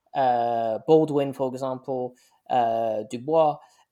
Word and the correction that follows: Du Bois: Sorry, Du Bois, yeah, I'm pronouncing French way.